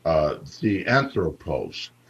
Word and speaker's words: theanthropos: Theanthropos, theanthropos. Now, that word is spelled T-H-E-A-N-T-H-R-O-P-O-S, theanthropos. T-H-E-A-N-T-H-R-O-P-O-S, theanthropos.